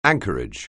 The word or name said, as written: anchorage